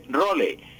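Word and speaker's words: rôle: En francés se escribe role. Role en francés, escrito así, pues se pronuncia rol, sin sonar la E final.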